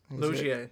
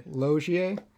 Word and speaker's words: Loggia: Robert Loggia. – Loggia.